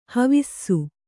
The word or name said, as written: ಹಾವುಸೆ